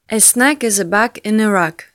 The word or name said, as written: as snug as a bug in a rug